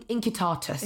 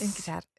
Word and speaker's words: Incitatus: Be- yeah, but like, is it, it's, it'd be, in, yeah, in, incitatus. – Incitatus.